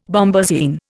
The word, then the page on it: bombasine: Indian